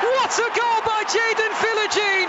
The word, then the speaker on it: Philogene: Is it Jaden Philogene or Philogene? – Philogene. – Philogene. – Philogene is way better, right? – And given away by Rob.